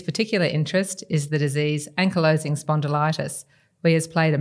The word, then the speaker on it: ankylosing spondylitis: Thanks for that introduction and well done on pronouncing ankylosing spondylitis.